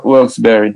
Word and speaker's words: Wilkes-Barre: Wilkes-Barre.